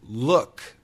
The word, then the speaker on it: look: L-O-C-K. Lock. Lock. Ah. Ah. That is the short O. Ah. Lock. Lock. And L-O-O-K. Look. Look. Look. That is the short double O. It's very close to a schwa sound.